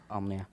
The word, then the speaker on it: Almia: Almia? – Almia.